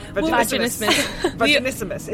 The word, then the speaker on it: Vaginismus: I've been practicing, and the way I have learned to say it is fast. Vaginismus. – Vaginismus. – It sounds like a dinosaur. – It rhymes with Christmas, so remember that.